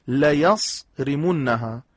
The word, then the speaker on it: ليصرمنها: خب إنا بلوناهم كما بلونا أصحاب الجنة إذ أقسموا ليصرمنها، در تلفظ این کلمه دقت کنن دوستان، ليصرمنها که عرض کردم لامش لام تأکید، نونم نون تأکید ثقيله است. ليصرمنها